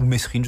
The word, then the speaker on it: Mesrine: À l'évasion de Jacques Mérine. Ou Mesrine, je ne sais plus comment on prononce. – On peut dire les deux, je crois.